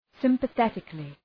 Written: sympathetically

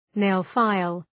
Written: nail-file

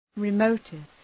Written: remotest

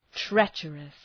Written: treacherous

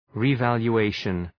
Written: revaluation

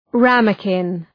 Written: ramekin

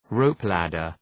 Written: rope-ladder

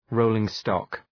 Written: rolling-stock

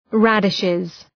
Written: radishes

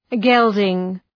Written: gelding

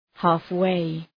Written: halfway

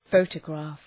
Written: photograph

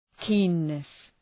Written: keenness

